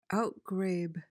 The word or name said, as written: outgrabe